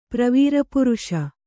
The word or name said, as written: ಪ್ರವೀರಪುರುಷ